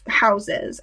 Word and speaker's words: Haus: They live in these very pretty dorms, which are like houses, and they're large and they're much nicer than I think most dorms, called house, but spelled like fashion house, like H-A-U-S.